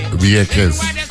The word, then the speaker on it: Vieques: Even though it's spelled with a V. If I don't know, the pronounce V with a B. Vieques.